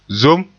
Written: zom

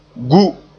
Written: gu

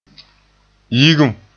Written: yigum

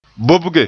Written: bobge

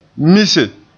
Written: mise

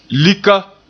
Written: lika

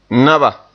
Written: naba

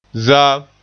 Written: zaam

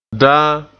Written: daan

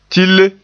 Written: tille

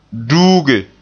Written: duuge

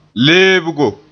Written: leebgo

